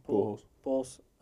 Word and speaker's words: Pujols: Pujols.